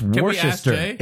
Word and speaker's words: Worcestershire: But there's an ire in there, isn't there? There's like Worcestershire. – Yeah, something. But it's not pronounced.